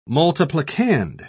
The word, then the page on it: multiplicand: British English